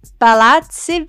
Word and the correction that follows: Palazzo: Versace. Did I say it right? – Palazzo? – Palazzo Versace.